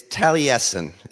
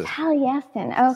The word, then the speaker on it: Taliesin: Taliesin West? Is that where you're working? – It's Taliesin. Is the Taliesin. Okay. Taliesin.